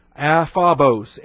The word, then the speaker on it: aphobos: Hina, H-I-N-A. Aphobos, A-P-H-O-B-O-S. You got the short O, Omicron, the long O, Omega. Hina, aphobos, genetai, G-E-N-E-T-A-I, genetai.